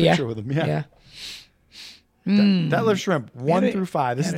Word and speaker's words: Schrempf: How do you say his last name? Schrempf. – I think you said it just perfect. Detlef Schrempf. – Schrempf. – Not Schrempf. I think you got to pronounce that F, I think.